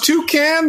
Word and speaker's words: Tocchet: The first one was Toucan. The second one was Toche. Like, yep.